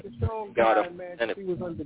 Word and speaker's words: Schoen: and that is Joe Sean from Buffalo, however you want to pronounce his name, Schoen, S-C-H-O-E-N. – Yes, and Sean, you got it right, baby.